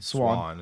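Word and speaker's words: Siuan: the horrible news because there was only assumptions at this point that the Amerlin had been replaced, but the idea of Swain, oh, can I say her name? – Swan. – Swan, thank you, being stilled and killed, like that is something that's been going around, but they hadn't heard that part of things.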